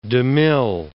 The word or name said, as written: de Mille